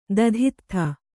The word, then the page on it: ದಧಿತ್ಥ: Kannada